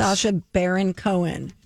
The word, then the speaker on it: Sacha Baron Cohen: Sacha Baron Cohen. Is that how you say it? – You're doing good.